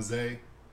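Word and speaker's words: Zae: I'm Zay.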